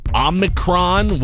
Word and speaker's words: Omicron: During the pandemic, how did you pronounce the variant Omicron, which is also the 15th letter of the Greek alphabet? Officially, almost anything works. Both Omicron and Omicron are fine.